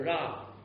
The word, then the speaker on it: ra: 意思这里是我不怕遭害，我不怕那恶者，或者不怕那个恶。这个恶在希伯来文的念法是 ra. – And the word evil in Hebrew is pronounced as ra.